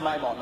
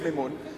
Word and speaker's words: Maimon: It's usually pronounced Maimon. It could be it's Mimun, but it is indeed a Spanish name.